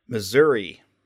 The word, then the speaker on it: Missouri: Missouri. – You say with a E sound at the end. – Yes, because there's an I at the end, and it's not an A, so.